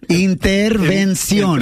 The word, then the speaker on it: intervención: Yeah, it's the N. Just get the N, Prima Vane. Intervención.